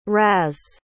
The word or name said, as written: Raz